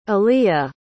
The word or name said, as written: Aaliyah